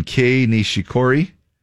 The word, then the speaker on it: Kei Nishikori: Kei Nishikori.